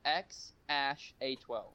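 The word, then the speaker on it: X Æ A-12: The name is pronounced XASH-A12.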